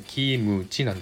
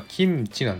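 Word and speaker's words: キ ム チ (김치): ところがありますのでそういったところはちょっと日本人には難しいかなと思います例えばですねキムチという言葉これ日本でもねよくキムチって言われてますけども日本語で発音すると KIMUCHI キムチなんですねでも韓国語ではこれはキムチ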